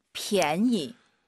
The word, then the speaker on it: 便宜: 便宜. – PIANYI인데요. 절대 PN이, 니은 발음 들리면 안 돼요. PN, 이, 便宜. 요거 기억하세요.